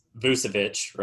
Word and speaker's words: Vucevic: It's Vucevic, right? Did I say that right? – Yeah.